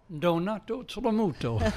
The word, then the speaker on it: Donato Tramuto: Donato Tedemuto.